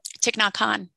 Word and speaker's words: Thich Nhat Hanh: That's how you say his name? – Thich Nhat Hanh. Yeah.